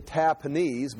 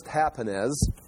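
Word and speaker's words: Tahpanhes: But I was going to say Tahpenes, but it's Tahpenes.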